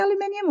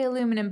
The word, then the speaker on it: aluminium: Do you say aluminium or do you say aluminum? – No, I say aluminum, but we also spell it aluminum.